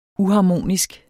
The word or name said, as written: uharmonisk